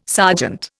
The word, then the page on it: sergeant: Indian